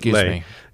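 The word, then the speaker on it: Leigh: So how will Linda Lee be programming that talk? – Yeah, so Linda Lay, I think she pronounces it Lay.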